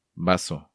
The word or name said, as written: Bazo